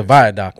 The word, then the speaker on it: viaduct: We were saying it wrong, but we until I looked it up in the dictionary, it's not a dock, but that's what we call it, the viaduct.